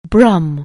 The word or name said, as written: Brum